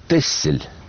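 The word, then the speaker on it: Texel: Дело все в том, что самый ближний Голландии остров, который пишется Тексель, а читается Тессель, в 15 веке получил статус и права города.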